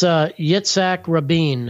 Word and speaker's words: Yitzhak Rabin: His name was Yitzhak Rabin. I'm probably butchering the name, but it's Yitzhak Rabin.